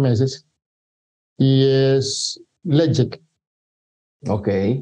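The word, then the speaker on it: Ledaig: Y se escribe Ledaik para los que nos estén escuchando y se pronuncia o se pronuncian los pronuncian Legic.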